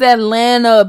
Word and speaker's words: Atlanta: I made sure I pronounced that second T for the people who not used to it. Girl, bye. – You know we don't say the second T. The second T does not exist. Yeah. It's Atlanta, baby.